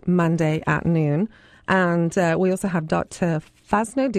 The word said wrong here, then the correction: Fasano: Is that the correct correct pronunciation, Eric? – Yeah, I think so. – Yeah. Well, he's coming to talk to us about... – Fasano. Yes.